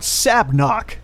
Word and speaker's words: Sabnock: So moving on, I want to talk about Sabnock. – How do you spell that? – S-A-B-N-O-C-K.